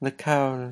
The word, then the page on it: lekaul: Lwaitel